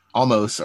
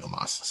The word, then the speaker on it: Omos: They even said once, almost, or I think they've changed the pronunciation. I think they're saying Omos now.